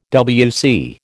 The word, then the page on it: WC: American English